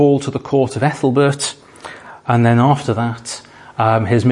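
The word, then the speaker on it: Æthelberht: I think that's pronounced Ethelbert, and that is the correct pronunciation, I have checked it carefully,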